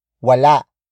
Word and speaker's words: wala: Wala sa kwarto niya ang susi.